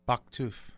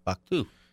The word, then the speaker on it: Bouktouf: Okay, you have to help me pronounce this. B-O-U-K-T-O-U-F. – Baktouf. – Baktouf.